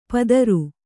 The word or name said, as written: ಪದರು